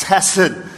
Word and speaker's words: hesed: Well, it's because this word used here, faithful love, it's the Hebrew word hesed, H-E-S-E-D, and we don't pronounce it with the hard H like it's supposed to be because it keeps moisture from going out in the front pews. So we just say hesed.